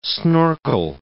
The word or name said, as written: snorkel